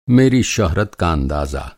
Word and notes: शोहरत: شہرت

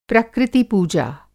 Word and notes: प्रकृति-पूजा: Hindi

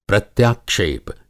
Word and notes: प्रत्याक्षेप: Hindi

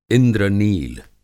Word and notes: इंद्र-नील: इंद्रनील

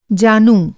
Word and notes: जानू: Hindi

